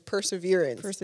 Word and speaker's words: perseverance: Preservation. – No, it's not preservation. – It's not preservation. It's perseverance.